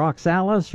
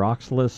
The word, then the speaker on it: oxalis: Now, in the case of your oxalis or oxalis, some people pronounce it each way, it's actually growing from a bulb rather than a seed, and your pre-emergents aren't going to do anything at all about it.